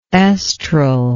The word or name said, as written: estral